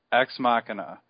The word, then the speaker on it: Ex Machina: So I had to go to the Merriam-Webster website and listen to it pronounced. Hang on. Machina. Ex Machina.